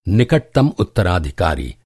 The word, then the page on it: उत्तराधिकारी: Hindi